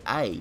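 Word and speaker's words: Et: I want to say A because in French, E.T. is pronounced A. It means and.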